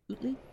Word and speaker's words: Utley: I'm saying that right? Utley? – I believe you are, yes.